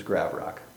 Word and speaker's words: Gravrock: It's, if you just think of the word gravel and the word rock and drop out the middle syllable, you'll have it. It's Gravrock.